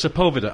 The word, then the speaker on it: Sepulveda: I know it's Sepulveda.